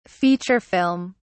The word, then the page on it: feature film: British English